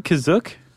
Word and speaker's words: Kashyyyk: Is that how you say it, Kizook? – That's how it's pronounced in the episode, the holiday special, but it's also pronounced different ways other times. – I would have pronounced it Kashyyyk.